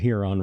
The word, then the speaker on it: Huron: See, you're with me on that too, because I've always said the only thing that Huron rhymes with is urine.